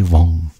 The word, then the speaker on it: Vaughan: Cole avec Everybody Dies de 2016 qui suivait en 1954, cette fois-ci, la légende, la seule et unique Sarah Vaughan. Je peux pas croire que je fais cette erreur-là. Ça s'écrit Vaughan, mais il faut dire Vaughan.